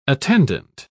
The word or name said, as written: attendant